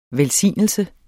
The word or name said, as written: Velsignelse